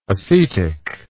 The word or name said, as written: acetic